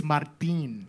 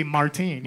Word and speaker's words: Martín: You see the little accent? It's Martin. – I like it. But you don't have to say it like that. You can just say Martin, you know, like as if it had an E at the end.